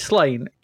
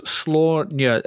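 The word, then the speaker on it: Sláine: It's Slain to me as well. But according to Wikipedia, it's pronounced Slaunia, SLAW-NYUH. I think it must be the Celtic pronunciation, Slaunia.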